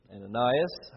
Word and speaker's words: Ananias: And then this man, Ananias is his name, Ananias, how it's commonly pronounced, who is the leader of the home, who had presented this money to the church, was struck dead by God.